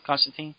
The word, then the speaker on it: Constantine: Then you got the little scene here with Constantine and I said it right that time? – I never know. – Constantine? – Yeah.